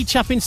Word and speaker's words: Chapin: So Harry Chapin's surname is pronounced Chapin.